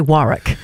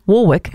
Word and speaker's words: Warwick: For anyone who's wondering about that, mostly when you see the name written like that, you say Warwick. – Because I have a bass guitar made by Warwick. – Yeah. – Spelled like that. – That's how most people say that word. But she famously says her surname, and it is her surname, Warwick.